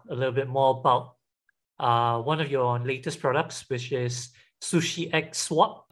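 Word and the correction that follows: SushiXSwap: I'm not sure if I'm pronouncing correctly. Hopefully I am. But it's actually Sushi Cross Swaps, but yeah. – Sushi Cross Swap. Yeah, see, I knew I wasn't getting it right.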